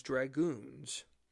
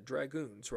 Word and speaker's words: dragoon: Colonel Banastre Tarleton's 180 Loyalist soldiers were also known as dragoons. You heard what I said, dragoons, right? Not dragons, but dragoons. Dragoon is spelled D-R-A-G-O-O-N.